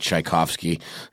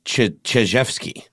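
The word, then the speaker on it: Chizhevsky: I keep seeing this name and I want to pronounce it Tchaikovsky. It's very close. It's Chijevsky. Yeah,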